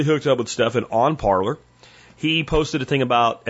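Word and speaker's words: Parler: I'm going to call it Parlor. That's what the guy that invented it calls it and then you parlay on Parlor.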